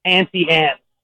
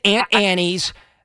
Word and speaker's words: Auntie Anne's: The correct pronunciation is Auntie Anne. – So we're both wrong. – You are both wrong. – Auntie Anne's. So I said Aunt Annie's.